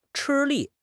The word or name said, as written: 吃力